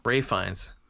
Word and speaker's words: Ralph: No, this says Ralph. – But that's... – Ralph Fiennes. It says Ralph Fiennes. – Ralph Fiennes. – Not how it's said.